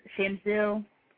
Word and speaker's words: Chamsil: I think it's actually pronounced uh, uh, Shamsil. It's spelled C H A M S I L.